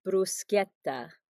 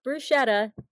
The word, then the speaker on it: bruschetta: So, for all of our listeners out there who don't know, it is bruschetta, not bruschetta, bruschetta.